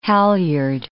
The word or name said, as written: halyard